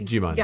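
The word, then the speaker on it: Djimon: It's Digimon. – Yeah. – It's a silent D, I think.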